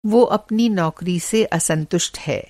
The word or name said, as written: असंतुष्ट